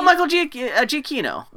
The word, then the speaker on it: Giacchino: Is that how you say his name? Giacchino? – Oh, Michael Giacchino. – Giacchino.